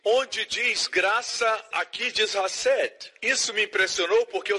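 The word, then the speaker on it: Hassed (חסד): E no Novo Testamento hebraico, ou desgraça diz Hassed. Tem que pronunciar com som gutural. Hassed. Diga Hassed para alguém.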